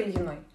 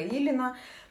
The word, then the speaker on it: Ильина: Я хотела, чтобы оно было очень простым, а, для тех, кто не знает, раньше наша мастерская называлась «Цветочная мастерская Веры Ильиной». А, фамилия произносится довольно сложно, на английском она читается как без мягкого знака, поэтому иногда меня называли Вера Илина.